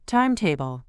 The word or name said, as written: timetable